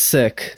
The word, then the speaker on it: Sikh: I mean, you can pronounce it Sikh, but it's pronounced Sikh.